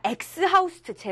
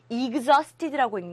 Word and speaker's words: exhausted: 막 또 이른다. 엑스하우스트 제발. exhausted라고 읽는 거예요.